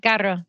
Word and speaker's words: carro: Caro and carro. – Yes. Yeah. You can roll your R's. I can stress it a little bit more, but either referring to an automobile or you're referring to something being expensive.